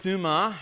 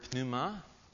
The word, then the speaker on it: pneuma: That comes from the Greek word pneuma, although in English we don't pronounce the P in the front of it.